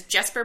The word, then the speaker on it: Jesper: His name is Jesper Bratt. And yes, it's a hard J because that's the weirdest thing.